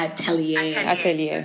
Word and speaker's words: Atelier: So, switching gears, you have a fashion line called Horses Outler. Am I pronouncing that? – Yeah. – Atelier.